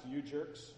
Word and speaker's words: UJ3RK5: Bands like UJ3RK5 were pronounced U-Jerks.